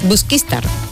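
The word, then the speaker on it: Busquístar: Busquistar. El acento. Me faltaba el acento.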